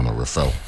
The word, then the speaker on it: Reffell: When I first asked him, I said Reffell, he said no, Reffell. So he said, you can pronounce it how you want. I said, no, it's like Chappelle.